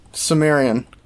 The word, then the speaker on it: Cimmerian: Cimmerian.